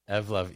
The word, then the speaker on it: Evlov: So Evlov. Evolve Evlov. – And love together. – Evlov, E-V-L-O-V. It's just like it's spelled.